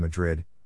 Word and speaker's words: Madrid: Our next stop was in the old mining town of Madrid, pronounced Madrid by locals, population about 200.